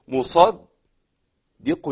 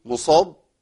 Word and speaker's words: مصدق: أنا عندي أي حرف مشدد في القرآن الكريم، أي مشدد، هو عبارة عن إيه؟ عن حرفين في النطق، بنطق الحرف الأول ساكن والثاني متحرك. طيب الاتنين زي بعض؟ آه زي بعض طالما في شدة. طيب الخطأ فين؟ الخطأ ان انا ابدل الدال الاولى بضاد، اقول ايه؟ مصاب دي، ما تنفعش.